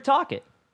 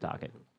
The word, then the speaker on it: Tocchet: Yeah, that's Rick Tocchet. I had to see it. I couldn't find it. No, that's how you say it. It's Rick Tocchet.